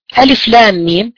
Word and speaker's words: الم: الآن قال الله عز وجل في أول سورة البقرة ألف لام ميم، هكذا تنطق. الألف اتفقنا أنها لا تمد، واللام اتفقنا أنها ستمد 6 حركات، والميم كذلك 6 حركات.